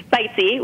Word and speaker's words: Bitesy: Bitesy, which, you know, rhymes with sightseeing, because that's I consider myself a culinary traveler.